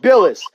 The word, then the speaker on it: Bilas: Am I saying his name right? – Bilas.